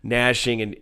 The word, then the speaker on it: gnashing: And so it's going to be gnashing and I know you don't pronounce the G in gnashing, gnashing and tiring those guys out early on.